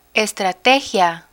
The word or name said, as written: estrategia